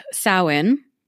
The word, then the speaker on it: Samhain: That's how the word is spelled, but it's actually a Gaelic word, I think, or Celtic in origin. And so it's pronounced Samhain.